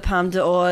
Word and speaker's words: Palme d'Or: I think things that go for the Palme d'Or as well. Oh, did I say that right? – Yes, you did.